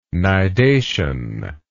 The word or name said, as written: nidation